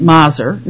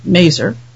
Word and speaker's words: Maser: Werner Maser, I've learned his name is pronounced Maser, not Maser, he